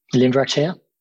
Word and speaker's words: Limvirak Chea: Limvarak Chia.